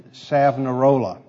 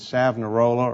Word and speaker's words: Savonarola: There was a group called the Waldensians, and secondly, a priest named Savonarola. Never quite sure how to pronounce his name, whether it's Savonarola or Savonarola. Heard it pronounced both ways, but I'll go with Savonarola.